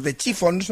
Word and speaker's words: The Chiffons: Años antes había triunfado otra creación, He's So Fine, compuesta por Ronald Mack e interpretada por The Chiffons, no sé si lo he dicho bien.